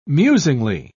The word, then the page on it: musingly: British English